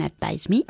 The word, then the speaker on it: advise: Do you know nobody who can advise me? – Advise me, con una V bien pronunciada. – Advise me. – Mucho mejor.